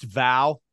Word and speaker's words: Vow: I thought about going with, I think it's pronounced Vow, V-O-W.